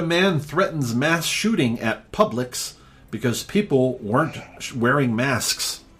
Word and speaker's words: Publix: And it's pronounced Publix.